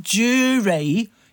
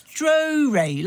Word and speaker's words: Drury: It's not Jury. You blockhead, it's Drury, like Drury Lane.